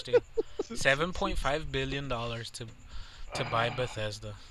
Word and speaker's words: Bethesda: No, like Bethesda.